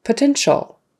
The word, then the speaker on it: potential: Potential. Again, that's just three syllables, not potential. It's just potential.